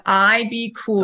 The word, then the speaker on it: IBKUL: It's called IB Cool.